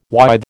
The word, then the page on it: withe: American English